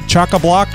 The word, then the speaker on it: chock-a-block: It's chock-a-block, a chock-a-pock. What do you call it? – Chock-a-block full of tech.